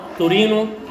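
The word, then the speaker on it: Torino: ما هي بتتنطق تورينو لكن بالانجليزي تورينتو او تورينوتينيسيس.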